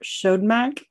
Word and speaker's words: Siodmak: Robert Siodmak, S I O D M A K.